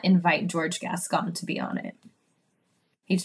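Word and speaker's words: Gascón: Gascon. – It reminds me of Beauty and the Beast.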